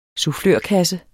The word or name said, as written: sufflørkasse